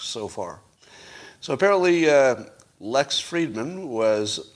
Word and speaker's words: Fridman: I'm seeing it's pronounced Fridman, thank you.